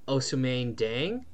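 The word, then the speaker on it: Ousmane Dieng: Yeah, man, and I think OKC did one of, like, the crazier moves of the draft when they drafted Osamane Dieng.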